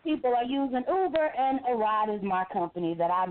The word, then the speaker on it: Aryde: So if anybody reads it, here's the pronunciation. It's called Aride, A-R-Y-D-E. Because you hear people say, I'll be over there when I get a ride.